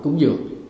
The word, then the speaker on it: cúng dường: Nhưng mà đọc thì đọc là cúng dưỡng, nhưng viết ra là viết cung dưỡng.